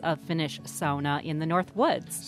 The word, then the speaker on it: sauna: Well, the way to go is sauna, as in the Finnish pronunciation. It's really the one Finnish word that is generally well-known in English, and so I think we should give tribute to them.